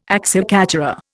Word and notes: acciaccatura: Indian

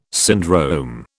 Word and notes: syndrome: British English

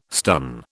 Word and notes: stun: British English

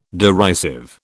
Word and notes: derisive: British English

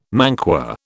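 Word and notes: manqué: British English

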